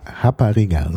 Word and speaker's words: rapariga: Also Portugiesisch Rapariga, so ist ausgesprochen, das R mit dem H ausgesprochen im Brasilianischen, ist in Brasilien die Prostituierte.